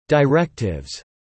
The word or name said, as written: directives